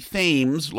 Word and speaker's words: Thames: the Thames, like the Thames in London, but they pronounce it, pronounce it Thames in Connecticut, to the Pequot.